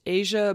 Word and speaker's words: Eja: This poor child's name is pronounced Asia, but it is spelled E-J-A, and I could not help but laugh.